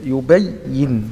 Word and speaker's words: يبين: الآن نطقتها أفضل من الأول حيث أنك شددت الياء يبين نلاحظ تشديد الياء هنا نعم أحسنت لعلكم تتفكرون.